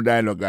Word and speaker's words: dialogue: Comme n'a pas mis des accents, sous bel accent en anglais, sous belle prononciation en anglais, le mot dialogue a gagné deux syllabes là-dedans. Daily Dialogue, alors, il y a pesé plus sous premier syllabe là. Daily Dialogue. Alors, accent tombé sous premier syllabe là.